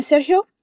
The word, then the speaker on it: Sergio: Lupe empieza preguntándole a Sergio, ¿terminaste el informe, Sergio? Primero quiero que practiques la pronunciación de su nombre. Repite, Sergio. La G va a sonar en tu garganta, el aire simplemente pasa por ella. Sergio.